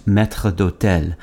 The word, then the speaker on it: maître d'hôtel: The term maitre d' is short for maître d'hôtel. In French, it's pronounced maître d'hôtel.